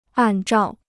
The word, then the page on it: 按照: àn zhào